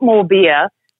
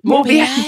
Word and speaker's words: Morbier: And I used to remember how to pronounce it by thinking that it was like, I'd like more beer. But it's not more beer. It's Morbier cheese.